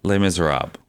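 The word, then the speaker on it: Les Misérables: Did I pronounce that correct? – Les Miserables.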